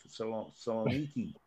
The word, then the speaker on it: Thessaloniki: James Jago from Aris Thessalonica in Greece. I think it's how you pronounce their name. Thessaloniki, actually.